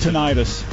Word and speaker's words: tinnitus: Tinnitus. – It's pronounced tinnitus, actually.